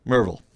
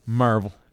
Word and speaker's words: Maryville: That's like here living in Maryville. – Right. – Yeah. It's Merville. – Merville.